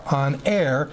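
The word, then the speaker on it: aner (ἀνήρ): Aner, almost like honor, but it's A-N, long E-R, aner.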